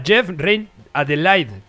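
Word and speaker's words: Jeff Reine-Adelaide: Ah, ya, Jeff Rein Adelaide.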